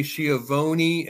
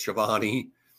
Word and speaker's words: Schiavone: She says, is it Tony Schiavone? And he's like, no, it's Schiavone.